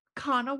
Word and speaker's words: Kanawha: Kanawha. – What does that mean? – That is named after a river that is spelled K-A-N-A-W-H-A. It's a Native American word, Kanawha.